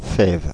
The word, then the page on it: fève: France (Paris); IPA: [yn fɛv]; French